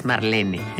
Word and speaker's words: Marlene: que todo el mundo dice Marlene, pero es Marlene.